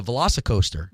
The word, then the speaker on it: Velocicoaster: That was the first time that I ever did the, and I hope I'm saying it right, but the Velocicoaster.